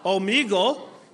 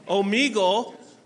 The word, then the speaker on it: Omegle: For example, I always say it wrong, so you can laugh at me, kids. I'm fine with this. But I'm pretty sure it's Omegle. How do you say it? Omegle. See, I'm fine being corrected.